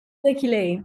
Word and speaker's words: Lykke Li: Leaky Lee. Is that how you say it, right? Is that, that's how you say her name, yeah? – Leaky Lee, yeah.